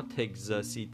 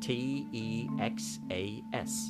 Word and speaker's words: Texas: T E X A S T E X A S تکساس Texas که تکس ما اکس داریم درسته؟ پس کس تکسس تکسس تکسس ولی ما میگیم تگزاس